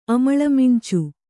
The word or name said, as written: ಅಮಳ್ಮಿಂಚು